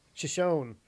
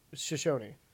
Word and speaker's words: Shoshone: Shoshone. – Shoshone. – It is spelled like S-H-O-N-E, so Shoshone. – Shoshone.